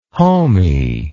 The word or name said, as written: homy